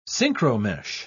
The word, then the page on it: synchromesh: British English